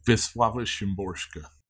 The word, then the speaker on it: Wisława Szymborska: Wisława Szymborska.